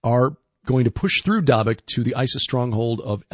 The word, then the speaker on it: Dabiq: Dabiq is, by the way, the correct pronunciation.